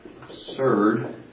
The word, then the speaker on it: absurd: And yes, last week we talked about him saying if something was, and I know I pronounced it wrong, not with a Z, is that right? But absurd. If something was absurd, he could believe it.